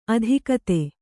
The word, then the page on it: ಅಧಿಗತ: Kannada